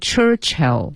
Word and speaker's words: Churchill: 应该读成 Churchill. – Yeah. – 一个是 Church, 一个是 Ill, 读成 Churchill. – Hill, Churchill.